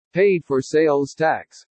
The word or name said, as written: PAID FOR SALES TAX